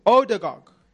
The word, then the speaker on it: Odegaard: I hope I say his name right. Oligarch. – Oligarch. – No, I'm saying his name so wrong. I'm butchering his name. I'm so sorry. Odegaard.